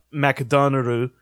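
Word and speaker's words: Makudonarudo (マクドナルド): But then with the chorus, it's all, like, borrowed words from English that are being pronounced like in the Japanese language way, right? So Makudonarudo would be the actual like way that you say McDonald's there, right? – Yeah.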